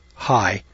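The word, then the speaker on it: Hsieh: All right, this is an essay that was written by Diana Mertz High, and I'm going to pronounce her name high. It's H-S-I-E-H.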